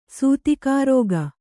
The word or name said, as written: ಸೂತಿಕಾರೋಗ